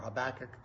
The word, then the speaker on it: Habakkuk: Now Habakkuk, or Habakkuk probably is a better pronunciation, was prophesying alongside Jeremiah also.